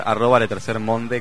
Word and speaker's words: Le Tercer Monde: También en nuestro Facebook, Le Tercer Mon, Pequeño Burgués Ilustrado, o por Twitter, @letercermonde con E final. – Que se dice Le Tercer Mon, pero se escribe Le Tercer Monde.